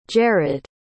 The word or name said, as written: jerad